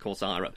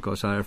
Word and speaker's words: Corcyra: And one of these is the stasis of Kokira. – I pronounce it Corcyra, but Keck Thank you very much. Yep. Well, I'll pronounce it Corcyra from now on.